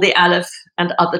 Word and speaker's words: Aleph: Aleph Contemporary. Did I pronounce it correctly? – Yes, you did.